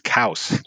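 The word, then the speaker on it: Coues: Apparently, cows, the French pronunciation is cows, like house.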